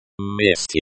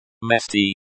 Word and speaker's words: месте: Месте. – Месте, place. The stress is on the first syllable.